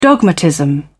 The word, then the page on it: dogmatism: British English